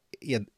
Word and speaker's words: Ed: Det är, det är verkligen så, det berättade när jag kommenterade Frölunda då, där så slog Johanna Dahlén fast för mig att det verkligen ska vara Ed, ja, eller Edström ska du väl egentligen Edström.